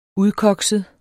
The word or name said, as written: udkokset